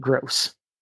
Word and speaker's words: Grose: His name, G-R-O-S-E, but I'm pronouncing it gross.